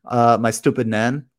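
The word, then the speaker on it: Estupiñán: I like, if you don't know how to pronounce Estupinan, listen to the Planet FPL Clash of the Correspondents. And Sam, the correspondent, he said, my stupid nan.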